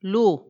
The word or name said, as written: lhu